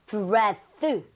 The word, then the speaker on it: breath: breath. – 어디까지? – 이거까지입니다. 예, 예. – 이가 안 붙고요. – breath. – 발음도 breathe가 아니라 breath. – breath. – breath. 이거 두 개는 주의하셔야 되겠죠? – 네.